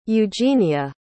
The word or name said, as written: eugenia